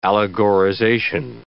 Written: allegorization

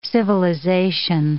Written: civilization

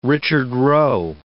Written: Richard Roe

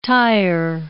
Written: tire

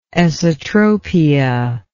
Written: esotropia

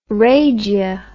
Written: rhagia

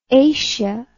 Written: acea

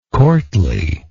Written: courtly

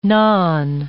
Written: naan

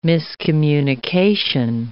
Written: miscommunication

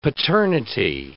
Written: paternity

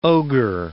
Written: ogre

